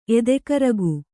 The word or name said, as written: ಎದೆಕರಗು